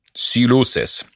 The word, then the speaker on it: Sylosis: Så sjekk ut det nye John Morland, og hvis du er metal-fan, Silosis Tror du uttaler det sånn? Sylosis.